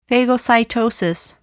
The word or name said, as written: phagocytosis